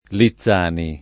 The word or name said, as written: Lizzani